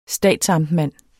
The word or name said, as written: statsamtmand